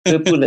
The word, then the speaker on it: Vipoola: Is that correct pronunciation, first of all? – Yes, absolutely. Vipoola is correct.